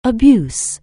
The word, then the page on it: abuse: anglais